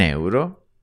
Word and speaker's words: euro: Now, the pronunciation of euro is a bit tricky. It took me ages to get it somewhere decently similar to how Italians say it. The secret is to separate out the E and the U at the beginning.